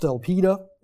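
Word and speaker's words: elpida (ἐλπίδα): The word hope in the Greek here is pronounced elpida.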